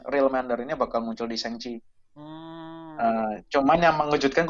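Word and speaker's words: Shang-Chi: Baca, bacanya Sangchi.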